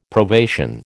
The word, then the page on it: probation: American English